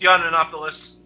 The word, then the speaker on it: Yiannopoulos: But I think it's Yiannopoulos.